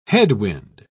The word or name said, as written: headwind